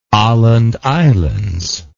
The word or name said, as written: aland islands